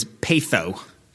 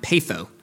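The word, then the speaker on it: patho (πείθω): And the word there, the Greek word, is patho. If you think of like a Mexican currency, the peso, and just mispronounce that a little bit with the TH sound, patho.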